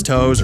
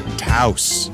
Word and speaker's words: Taos: It's not Taos or Taos. And if you think it's Toes or Toss or Toast, then come on, you're not even trying. It's the all-new compact SUV, the Volkswagen Taos.